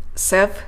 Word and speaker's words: several: ジェネラルであれば general general セブラルであれば several several ラテラルであれば lateral lateral と発音します。 R から L への音移動、ぜひマスターしてこの辺りの単語かっこよく発音できるようにしてみてくださいね。